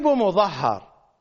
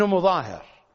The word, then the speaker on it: مظاهر: مظهر، إنه حبيب ابن مظاهر، في بعض النسخ هكذا يكتب، تصحيف، التسمية الصحيحة حبيب ابن مظاهر.